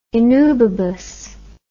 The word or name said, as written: innubibus